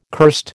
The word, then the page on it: cursed: American English